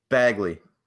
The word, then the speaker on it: Bagley: Is it Bagley or Bagley? – Bagley.